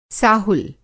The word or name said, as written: साहुल